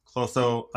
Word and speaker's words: Clotho: And in the Greek mythology, it's Clotho, Atropo, and Lachesis. – Excellent Greek pronunciation there.